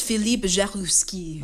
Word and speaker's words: Philippe Jaroussky: Am I saying that correctly? – Yes, Philippe Jaroussky.